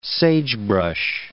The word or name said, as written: sagebrush